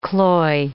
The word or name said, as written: cloy